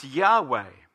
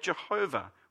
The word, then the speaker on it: Yahweh: The word in Hebrew we think is pronounced Yahweh. Older translations had Jehovah. We don't know exactly how to pronounce it because the vowels were not pointed into the manuscripts of the Old Testament.